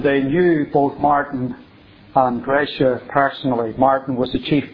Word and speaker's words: Gracia: This book, In the Presence of Mine Enemies, is the book written by Gracia, as it's properly pronounced, Gracia Burnham, the wife of Martin Burnham, the couple that were taken hostage by the Agdeus-Seif rebels in the Philippines.